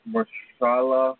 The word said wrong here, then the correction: Mahershala: Ali. Can you let me know Mahershala. – Mahershala. – There we go.